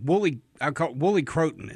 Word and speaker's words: woolly croton: I remember we hunted them at a clear cut where it butted a right away, and there was that woolly croton. Is that how you say it? Woolly croton? – Right.